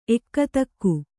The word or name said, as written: ಎಕ್ಕತಕ್ಕು